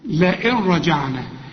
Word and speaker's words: لئن رجعنا: لئن رجعنا، النون هنا بقى سكنة وبعدها راء، كل نون سكنة بعدها راء الغيها من النطق عند الوصل، العلماء بيسموها ادغام كامل بدون غنة، وانا بريحك بقول لك اشبك الهمزة دي بعدها راء على طول، لئن رجعنا، ما تغنش، واذا وقف لئن تثبت النون، رجعنا